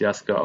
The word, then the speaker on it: Yasuke: Der Soundtrack zu einer Serie, die auf Netflix läuft und die heißt wie das Album Jaske, also Yasuke geschrieben. In der Serie wird es Jaske ausgesprochen und das ist auch der Name des Hauptcharakters der Serie, ein schwarzer Samurai, den es wohl auch wirklich gegeben hat im 16.